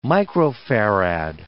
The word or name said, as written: microfarad